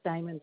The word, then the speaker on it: Diamond: It's spelled L-I-L-E-T-T-E. – Mm-hmm. – My last name is Diamond, just like a ring.